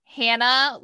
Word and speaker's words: Hannah: She said we can say it like Hannah. – Okay. Hannah Larson.